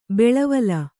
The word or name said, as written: ಬಾಳ್ವಲಿ